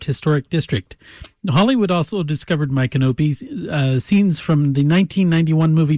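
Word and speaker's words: Micanopy: Now, here's one that's difficult to pronounce, but it's My Canopy, Florida. You got to get the emphasis in the right syllable.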